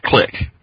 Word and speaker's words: Kliq: All I know as a wrestling fan is that it's pronounced Click. – Well, that's because it's spelled K-L-I-Q in wrestling.